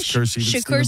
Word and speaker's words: Shakur: Yes, Shakur Stevenson.